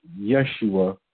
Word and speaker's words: Yeshua: So when I'm saying yes, yes, Y-E-S, Yeshua, I consciously know what I'm doing because I was taught this. Because that would be the more proper way to pronounce it, particularly for an English translation.